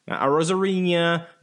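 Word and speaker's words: Arozarena: And also, if we're gonna get tiki-taki, I hate how everybody's mispronouncing Arosarena. Arosarena, not Arosarena, or Rosarra.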